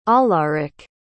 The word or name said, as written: alarik